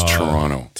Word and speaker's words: Toronto: So you do not pronounce the second T. – Toronto? – It's Toronto. A lot of people will be like, Toronto.